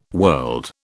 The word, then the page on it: world: British English